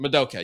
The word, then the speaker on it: Madoka: Well, at this very moment, I'm looking at it right now, it looks like Sailor Moon versus Madoka? Madoka? I've watched this. Madoka. I don't know how to pronounce it for some reason. Madoka, yes.